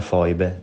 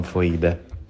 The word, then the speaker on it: foibe: E si pronuncia Foibe, non Foibe.